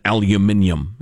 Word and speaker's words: aluminium: So, look, here's the way I see the tariff thing with steel and aluminium, as I prefer to pronounce it.